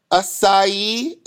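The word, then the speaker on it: açaí: You know, just like you start seeing acai. Am I saying it right? – Yeah, the acai berries or whatever it is.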